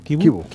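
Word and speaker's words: Chivu: Se pronuncia así, ¿no? – Kibu.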